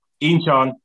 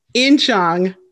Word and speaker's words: Incheon: Incheon. – Incheon, South Korea.